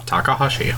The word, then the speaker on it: Takahashi: Takahashi.